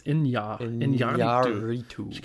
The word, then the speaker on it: Iñárritu: It's got the tilde on it, so it's Iñárritu.